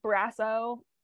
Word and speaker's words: Brasso: When what's his name, Brasso, is that how you say it? – Yes.